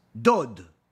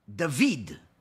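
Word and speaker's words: David (דוד): it's spelled Dalit Vav Dalit, which typically would be read as Dod or Dud, but yet it's read David, where the Vav has a Chirik, an E sound, as if it has also the letter Yud, where it's Dalit Vav Yud Dalit.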